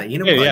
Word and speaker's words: Enumclaw: Is it Enumclaw? Am I saying that right? – You got it. Yeah, Enumclaw.